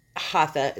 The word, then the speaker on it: Hatha: But I saw stuff like Hatha. Is that how you say it? – Yes. – Okay. – Exactly. – Oh, yeah, I said it right.